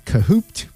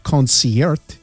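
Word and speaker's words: Concert (Концерт): The name of that album, a lot of people call it Kahoot because that's how it's spelt, but it's actually Concert, which is the Russian word for concert.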